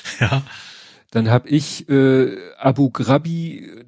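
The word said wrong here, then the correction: Abu Ghraib: das heißt, und äh, das das heißt Abu Graib.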